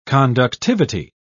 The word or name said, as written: conductivity